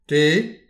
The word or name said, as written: te